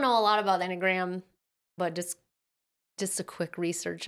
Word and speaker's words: Enneagram: I don't know why she just, oh yeah, she jumps into making a point about the, how do you say it, enneagram? – Enneagram is what I would say.